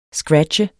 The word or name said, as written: SCRATCHE